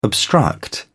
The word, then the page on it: obstruct: British English